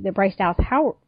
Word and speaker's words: Bryce Dallas Howard: But the intention of the director was that she was the Bryce Dallas Howard.